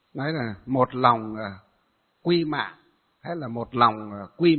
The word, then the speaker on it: mạng: Nó vẫn là một cái chữ Hán ấy, nhưng ở miền Bắc chúng ta đọc là mệnh, còn ở miền Nam đọc là mạng.